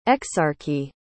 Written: exarchy

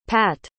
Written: patt

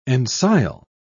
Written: ensile

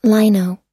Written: lino

